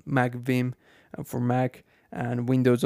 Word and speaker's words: Vim: I use the application Beam, uh, Vim, yeah, sorry, I said it wrong, Vim, which is spelled V I M.